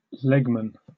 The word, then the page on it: legman: British English